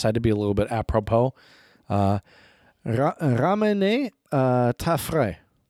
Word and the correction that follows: fraise: How do you say strawberry? Is it fraise? – Because there's an E at the end, right? – Yes. So, ramener ta fraise.